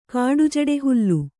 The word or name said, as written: ಕಾಡುಜಡೆಹುಲ್ಲು